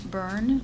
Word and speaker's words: Byrne: Yes, my name is Deborah Ann Byrne. Byrne is spelled B-Y-R-N-E.